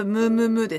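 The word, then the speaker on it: むムM: 読み方としては全部ムームームーですよね。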